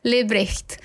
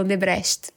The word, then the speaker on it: Lebrecht: And yeah, my father was born in Brazil, but for Brazilians to pronounce my last name is also hard. So right. Don't worry about it. Lebrecht. – Okay. – Yeah, what do we say in Brazil? Lebrecht, for example.